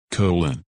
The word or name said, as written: Colin